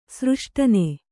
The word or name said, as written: ಸೃಷ್ಟನೆ